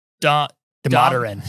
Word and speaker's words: Damodaran: So Oswath Dalmadalin. – Damodaran. – Damodaran.